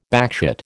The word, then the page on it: backchat: American English